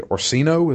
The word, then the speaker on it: Orsino: And then the mages, and particularly Orsino, is that how you pronounce it? – Yep. Yeah, I think so.